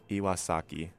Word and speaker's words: Iwasaki: And before that, for our background music, you heard Awake by Taku Iwasakai? Oh my gosh. Sorry, guys. I'm really bad at pronouncing anything that, like, isn't a normal word in the English dialect. Taku Iwasaki. I don't know why that took me so long to pronounce.